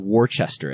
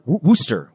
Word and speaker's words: Worcester: It's not Worcester as it appears. It's Worcester.